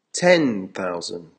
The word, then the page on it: ten thousand: American English